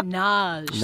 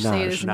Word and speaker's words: Nagy: just Nagy. – Nagy. I mean, it's so pleasing. It's like Nas, but Right, right, exactly.